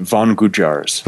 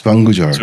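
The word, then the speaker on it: Van Gujjar: The tribe, they're called Vangujars. – Vangujars. – So it's two separate words. Van is spelled like a van you would drive around in, and Gujar is the second part of that.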